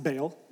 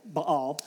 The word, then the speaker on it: Baal: You might have heard it pronounced Baal, Baal.